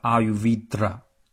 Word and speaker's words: Ayurveda: 这呢，就不得不提到印度古老的一个医学体系，叫阿育吠陀，英语叫 Ayurveda。我这个小舌音不太准啊，应该用小舌发音。